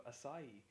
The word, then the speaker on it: acai: And I'd like to give a special thanks to my friend Fiona for enlightening me on the correct pronunciation of acai. Pronounced acai! Acai, not acai.